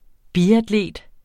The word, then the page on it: biatlet: ˈbiadˌleˀd; biadˈleˀd